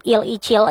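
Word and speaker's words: Il Ichil (일이칠): So next teman Perudu, di hari yang sama juga nih, NCT Il Ichil nih, kalau cari tahu ternyata bacanya tuh NCT Il Ichil. Tetap aja 127 ya teman Perudu, tapi dalam bahasa Koreanya.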